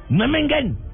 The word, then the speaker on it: Memmingen: permita, permita, ¿cómo se pronuncia? – Memmingen. Ya. Don Abe, se pronuncia como acaba de decir yo.